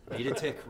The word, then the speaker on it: Videtich: Videtich rhymes with kick.